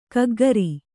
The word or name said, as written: ಕಕ್ಕುಗುರು